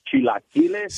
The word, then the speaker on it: chilaquiles: Claro que and you're saying chilaquiles.